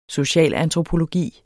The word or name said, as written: socialantropologi